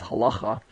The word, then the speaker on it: halakha: So essentially, you'll hear this term halakha, or sometimes you might hear it pronounced as halacha, or in Jewish circles, it's the halacha.